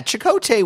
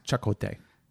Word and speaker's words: Chakotay: Well, yeah, Chakotay was – I'm sorry. It's pronounced Chakotay.